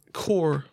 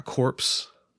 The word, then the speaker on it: Corps: Why is Corps pronounced Corps and not Corpse? – I don't know.